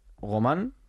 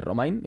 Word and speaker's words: Romain: Romain. De Romain, igual que Bardet.